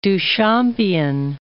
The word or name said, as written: Duchampian